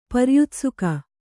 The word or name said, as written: ಪರ್ಯುತ್ಸುಕ